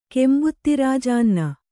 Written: ಕೆಂಬುತ್ತಿರಾಜಾನ್ನ

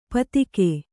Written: ಪೈತ್ತಿಕ

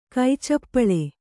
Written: ಕೈಚಪ್ಪಾಳೆ

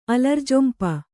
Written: ಅಲರ್ಜೊಂಪ

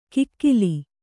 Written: ಕಗ್ಗಾಲ

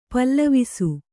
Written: ಪ್ಲವಿಸು

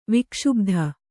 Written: ವಿಕ್ಷುಬ್ಧ

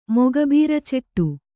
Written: ಮೊಗಬೀರಚೆಟ್ಟು

